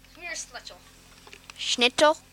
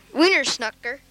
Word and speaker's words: Wiener Schnitzel: Wiener Schnitzel. – Wiener Snucker. Wiener Sucker.